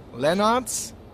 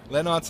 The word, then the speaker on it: Lennarz: If you want to follow me on social media, on Instagram, it's H Lennartz. Lennartz is spelled L-E-N-N-A-R-Z. It's a little bit complicated, sorry, but it's not my fault. It's my name. And or on Twitter, it's just Lennartz, or on Facebook, just have a look.